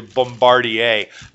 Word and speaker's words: Bombardier: I think it's actually Bombardier.